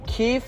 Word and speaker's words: kief: For the listeners that don't know, keef, which is... In actuality, pronounced, well, not pronounced, but spelled K-I-E-F. – Not K-E-E-F-E. – Or K-E-E-F. K-E-E-F.